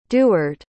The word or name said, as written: deward